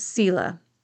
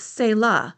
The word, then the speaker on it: Selah: I've always said Selah, but I've also heard it pronounced Selah.